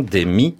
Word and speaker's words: Demme: Demme.